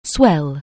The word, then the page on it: swell: anglais